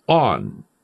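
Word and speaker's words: on (ἄν): And it's contained in an untranslatable particle that is pronounced on, A-N, which means that there are limiting factors to this phrase.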